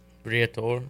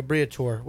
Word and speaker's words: Briatore: Alonso strategically positioned himself for a possible move to Mercedes, leveraging his influential manager, Flavio... man, I'm going to butcher this. – Briatore. – There you go, Briatore.